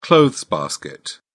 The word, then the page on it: clothes basket: British English